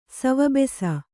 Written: ಸವಬೆಸ

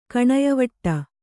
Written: ಕಣಯವಟ್ಟ

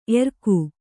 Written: ಏರಿಕ್ಕು

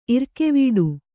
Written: ಇರ್ಕೆವೆಟ್ಟು

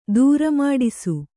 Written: ದೂರಮಾಡಿಸು

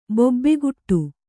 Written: ಬೊಬ್ಬೆಗೊಡು

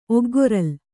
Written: ಒಗ್ಗೊರಲ್

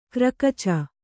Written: ಗುರುಕುಚ

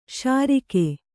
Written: ಷೆರೀಕು

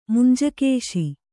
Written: ಮಂಜುಘೋಷೆ

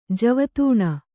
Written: ಜೀವಧನ

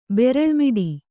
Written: ಬೆರಲ್ಮಿಡಿ

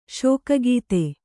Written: ಶೋಕಗೀತೆ

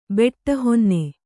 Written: ಬೆಟ್ಟಹೊನ್ನೆ

